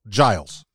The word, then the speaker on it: Giles: And it's simple. Giles. I mean, I can understand doing a hard G. – Giles. – But I mean, I would get Giles. I would get Gillis. I would get Guys. Gillies.